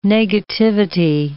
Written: negativity

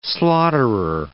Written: slaughterer

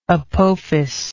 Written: apophis

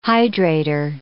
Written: hydrator

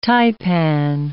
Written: taipan

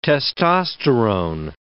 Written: testosterone

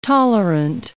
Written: tolerant

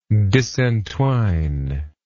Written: disentwine

